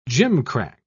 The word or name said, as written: gimcrack